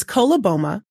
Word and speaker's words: coloboma: It's coloboma.